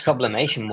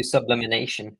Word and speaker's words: sublimation: There's always somebody that says, I have a sublimatic printer, or I do sublimination.